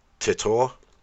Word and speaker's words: Titor: His name was Titor. – I think you'll find it's pronounced Titor.